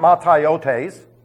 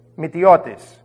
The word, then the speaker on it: mataiotes: It is not Mataiotes. That sounds good on tape. But if you said that to a Greek, he'd laugh in your face. It's Mitiotis. Let me spell it for you. M-A-T, Mitiotis, M-A-T-A-I-O-T-E-S.